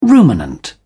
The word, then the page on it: ruminant: British English